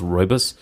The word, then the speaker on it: rooibos: It's like R-O-O-I-B-U-S, I think, but it's pronounced rooibos.